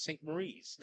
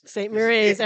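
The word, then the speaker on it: St. Maries: Oh, I'm very familiar with St. Mary's. In fact, but I tend to call it St. Marie's. – St. Marie's. Everybody says that. – Right, that's the way it's spelled.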